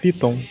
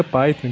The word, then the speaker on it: Python: Ah, esqueci de novo, é Python. – Isso. – Python. É igual a cobra. Certo. É porque eu sempre ouvi a pronúncia Python, entendeu? Que é em inglês, então eu nunca lembro.